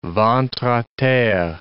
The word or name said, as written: ventre a terre